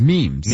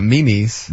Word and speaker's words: memes: Like, how do you guys pronounce it? It's memes. – Memes. Yeah. – Okay. – Memes. – So we're all on the same page. – Not Mimi's.